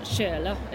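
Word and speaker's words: källa: Kayla means, or Shirla, as they pronounce it, it means um, water spring or source.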